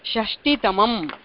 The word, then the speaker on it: षष्टितमम्: इत्युक्ते षष्टितमम् इति वदामश्चेत् तत्र अल्पप्राणटकारः उच्चारणीयः.